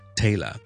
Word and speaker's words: Taylor: OK，第一个问题咧就系咧好简单嘅，Taylor 姓咧 Taylor T A Y L O R Taylor，同埋一个裁缝啊 T A I L O R Taylor 个发音系咪一样嘅咧？答案系正确，冇错，系一样嘅 Taylor Taylor。